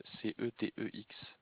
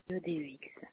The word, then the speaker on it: CEDEX: Collégien CEDEX, comme ça se prononce, collégien comme un collégien, CEDEX, CETEX. – CEDEX.